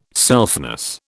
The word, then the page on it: selfness: British English